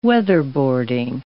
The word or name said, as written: weatherboarding